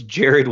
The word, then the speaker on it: Jarrid: So it's Jarrid Wilson. It's J-A-R-R-I-D Wilson.